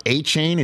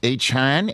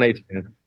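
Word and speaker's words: Achane: It's not A-Chain, it's A-Chan. Is that Devin's last name? Is that correct? – Yeah, Devon A-Chan. – Devon A-Chan.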